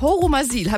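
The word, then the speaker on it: Horumersiel: Im schönen Örtchen Horumasil. Habe ich das richtig ausgesprochen, Christian? – Ja, das ist absolut korrekt.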